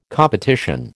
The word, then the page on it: competition: American English